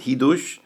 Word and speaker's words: Chidush: For this, there's two organizations, Chidush, which is like Chidush, but that's how they pronounce it, Chidush,